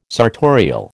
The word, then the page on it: sartorial: American English